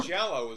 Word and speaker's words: Giallo: How do you pronounce Giallo film? Giallo film? – Giallo is how I would pronounce it.